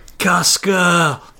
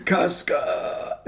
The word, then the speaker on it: Casca: Casca! – Sorry, I'm going to pronounce it correctly. Casca is,